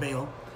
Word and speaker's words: Baal: Or we thank Baal, or it's usually pronounced Baal, for the food that was sacrificed to him.